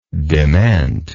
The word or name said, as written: dement